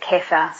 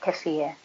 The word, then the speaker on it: kefir: And some people pronounce it kefir, some people pronounce it kefir. It's K-E-F-I-R, yogurt.